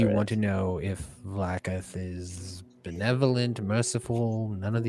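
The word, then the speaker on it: Vlaakith: H Vlakif.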